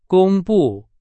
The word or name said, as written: 公布